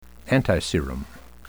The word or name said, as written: antiserum